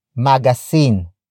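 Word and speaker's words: magasin: Magazine, ang, binasa, nya.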